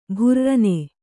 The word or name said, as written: ಭುರ್ರನೆ